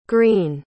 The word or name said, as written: greene